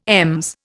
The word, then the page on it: EMS: Indian